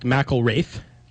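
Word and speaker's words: McIlrath: One, it's pronounced McElraith. – Okay, there you go. I don't know why it's a Mac if it's MC. Wouldn't it be Mick? But anyways. – Yeah, it's M-A-K according to the pronunciation.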